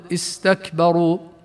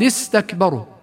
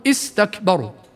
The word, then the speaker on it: استكبروا: من الأخطاء الشائعة لدى كثير من الناس أنهم ينطقون همزة الوصل بهمزة قطع في بعض أو كثير من الكلمات، فيقولون مثلا لقد استكبروا فيقطعون الهمزة إيه، مع أن قراءتها يجب أن تكون همزة وصل دون قطع، فتصبح الهمزة وكأنها غير موجودة فلا تنطق أبدا في حالة الوصل، وذلك بسبب التقاء الساكنين، فنقول لقد استكبروا. أما إن بدأنا بالكلمة من غير وصل فننطق بالهمزة حينها ونقول استكبروا. ومثلها والتي قد يخطئون بقراءة همزة وصلها أمر تابوا دون ما نذكر كيف يخطئون، ولا نذكر أم ارتابوا مثلا، أم ارتابوا الأصل.